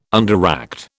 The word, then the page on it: underact: British English